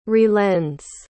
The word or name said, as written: relends